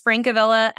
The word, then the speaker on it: Francavilla: So my last name's Francavilla, F-R-A-N-C-A-V as in Victor, I-L-L-A.